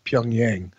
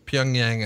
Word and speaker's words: Pyongyang: But I think the consensus is, at least here in the United States, it's pronounced Pyongyang. Okay. I've heard it Pyongyang, Pyongyang. I've heard it different inflections, but I say Pyongyang.